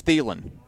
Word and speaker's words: Thielen: They always have pronunciations of names. It says Thielen.